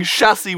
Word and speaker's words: chassis: And he kept pronouncing chassis with a hard ch.